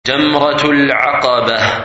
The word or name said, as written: Jamrat Al Aqabah